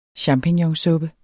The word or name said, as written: champignonsuppe